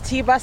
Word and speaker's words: Tiba: Hi guys, my name is Tiba Salvatore, T-I-B-A, Salvatore, S-A-L-V-A-T-O-R-E.